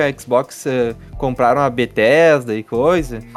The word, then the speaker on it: Bethesda: Não sei como é que fala o nome da empresa. É, da Bethesda.